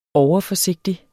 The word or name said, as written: overforsigtig